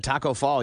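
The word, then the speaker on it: Tacko Fall: But there's a guy that Taco Fall, you're going to see.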